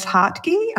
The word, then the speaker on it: Hartge: It's Ness Hartge, and it's German. Dad's German, so yeah.